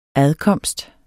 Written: adkomst

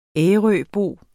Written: ærøbo

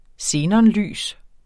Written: xenonlys

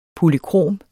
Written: polykrom

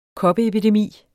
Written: koppeepidemi

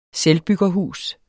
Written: selvbyggerhus

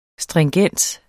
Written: stringens

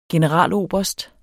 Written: generaloberst

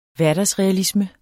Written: hverdagsrealisme

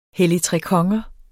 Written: helligtrekonger

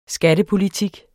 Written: Skattepolitik

